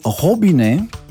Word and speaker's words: robinet: Piccolo Montone è il nome francese del rubinetto, o meglio, rubinetto deriva dal francese robinet. – Eh? – Vabbè, facciamolo pronunciare a chi lo sa pronunciare. – Robinet. – Più o meno l'ha detto come me.